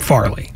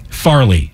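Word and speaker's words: Fairleigh: I believe they call it Farley. – Farley. Spelled fairly. – Yes. – Call it Farley. Farley Dickinson. Not Mr. Furley.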